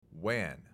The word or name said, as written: WAN